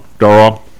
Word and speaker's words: Darrell: No, Andrew has no idea that's Darrell Royal. That's the Texas Darrell. That's how you pronounce the guy's name if you're from Texas. And so this dude, he was a pretty big guy.